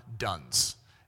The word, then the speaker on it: Duns: We go back to the 1200s, and you have a guy named John Dunce. Now, his name was spelled D-U-N-S, but they would have pronounced it Dunce.